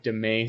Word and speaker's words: demesne: Domain. He spells it D-E-M-E-S-N-E, Demesne, but it's pronounced domain.